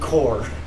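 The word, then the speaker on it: Corps: We also have Contra Rogue Corpse for PlayStation, Xbox, Switch, and PC. – Rogue Core. – Corpse. – Core. – Corpse. There's a P. – Yeah, you don't pronounce it.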